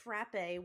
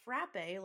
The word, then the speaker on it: frappe: Instead of it being pronounced frappe like it would with the accent, it's now pronounced frap because there's no accent. It's just F-R-A-P-P-E. No accent aigu.